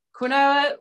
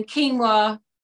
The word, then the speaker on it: quinoa: So we call it quinoa when it's growing, and then quinoa when we're selling it.